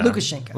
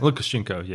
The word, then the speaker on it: Lukashenko: Is it Lushenko? – Lushenko. – Yeah. – Lukashenko. – Lukashenko, yeah. – Yeah. Lukashenko.